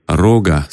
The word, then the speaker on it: рога: Какой-нибудь начинающий разбойник, или, как его чаще называют в игре, рога с ударением на первый слог.